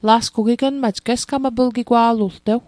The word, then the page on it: gesgmaplgigwa'latl: Mi'gmaq